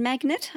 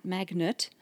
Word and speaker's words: magnate: magnate, I guess, and business magnate. Magnate, not magnet.